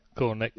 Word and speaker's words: Cornet: I don't know how to pronounce it. – Cornet.